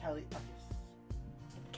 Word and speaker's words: Kali Uchis: That you said it right. – I said Kali Uchis. – Kali Uchis.